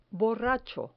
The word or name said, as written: borracho